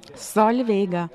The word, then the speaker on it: Solvega: Solvega. – Solvega? – Light L. – Light L? – Soft L. – Oh, soft L. Okay. Say it again. – Solvega.